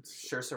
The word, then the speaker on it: Saoirse: And then Willem Dafoe and Saoirse Ronan. I always put the "sh" on the wrong syllable, but yeah, she's my main problem. – Like Saoirse Ronan.